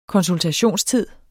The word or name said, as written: konsultationstid